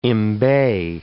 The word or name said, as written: embay